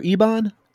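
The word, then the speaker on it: Eibon: Is that correct, Roger? Ebon? – Yeah, I think that's how it's pronounced.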